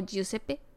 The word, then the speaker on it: Giuseppe: Jisupi, sí. ¿No es Giuseppe? Mm, o Giuseppe.